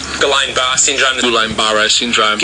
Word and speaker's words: Guillain-Barré syndrome: Guillain-Barré syndrome.